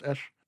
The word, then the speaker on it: Esh: This precinct is written with the cuneiform sign Ab, pronounced Esh, and it apparently housed both temples and administrative buildings, if there is a distinction between those two things.